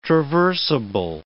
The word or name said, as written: traversable